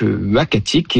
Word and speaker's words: Uhackatic: Alors, le nom, ce sera donc Uakatik, et ce nom-là, donc il s'écrit U-H-A-C-K-A-T-I-C, nous a été proposé par le conseil des Inuits d'Ekunitchit, qu'on a contacté justement pour avoir leur avis.